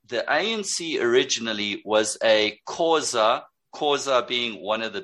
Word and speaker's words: Xhosa: That's the X-H-O. – Yes. – Yes. Khoza is X-H-O-S-A is how, and it's pronounced Khoza, like yes. – A K. – You pronounce it like a K.